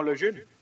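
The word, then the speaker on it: Lejeune: Bueno, pues si lo pronunciamos la francesa, debería ser algo así como Le Jeune.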